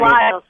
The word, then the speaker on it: Lyall: You have a case here, Suzanne Linal. I may not be pronouncing her last name correctly. It's L Y. – Oh, Lyle, Suzanne Lyle, yeah. – Ann Lyle, okay. Spelled L Y A L L.